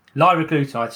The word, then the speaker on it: liraglutide: Liraglutide.